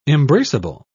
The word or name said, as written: embraceable